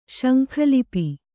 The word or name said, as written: ಶಂಖಲಿಪಿ